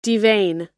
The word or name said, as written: devein